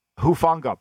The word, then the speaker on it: Hufanga: Hufanga.